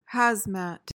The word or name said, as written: hazmat